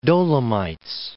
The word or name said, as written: Dolomites